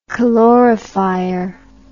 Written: calorifier

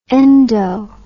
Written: endo

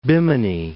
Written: Bimini

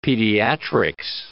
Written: pediatrics

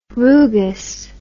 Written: rugous

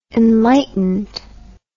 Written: enlightened

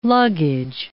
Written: luggage

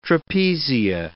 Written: trapezia

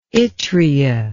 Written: yttria

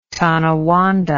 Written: Tonawanda

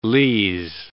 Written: lees

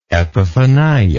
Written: epiphania

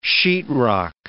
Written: Sheetrock

